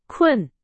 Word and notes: 困: Chinese

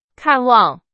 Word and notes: 看望: Chinese

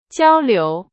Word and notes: 交流: Chinese

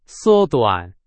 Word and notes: 缩短: Chinese

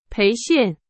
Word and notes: 培训: Chinese

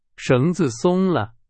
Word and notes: 松: Chinese

